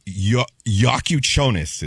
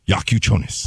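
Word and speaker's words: Jakučionis: And then the guard, Yakuchonis is how you say his last name. Yakuchonis.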